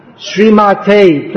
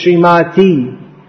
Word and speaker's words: Srimate: Srimate. Please don't pronounce this Srimati and convert Srila Prabhupada into a female.